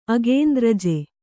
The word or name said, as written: ಅಗೇಂದ್ರಜೆ